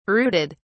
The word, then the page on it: brooded: British English